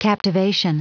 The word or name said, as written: captivation